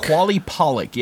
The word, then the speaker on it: Qalupalik: Quali uh, Pollock? Uh, Qalupalik, yeah.